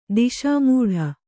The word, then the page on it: ದಿಶಾಮೂಢ: kannada